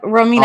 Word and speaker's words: Romina: Yep, Romina.